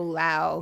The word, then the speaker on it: Lowe: And that would be Josh, but your former pick, Brandon Lau, and they're both spelled the same, but it's a different pronunciation. So Low Lau.